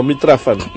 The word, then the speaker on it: Mitrofan: I aleshores diguéssim que ens presenta un avi, que no sé mai si se l'inventa o és de veritat, que és en Mitrofan, ho pronuncio bé, Mitrofan? – No, Mitrafan.